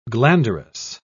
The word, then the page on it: glanderous: British English